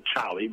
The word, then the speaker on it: Cholly: And the Charlie, which is spelled C-H-O-L-L-Y, is a name my father gave me as a baby.